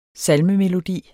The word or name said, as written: salmemelodi